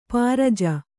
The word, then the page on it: ಪರಿಜು: Kannada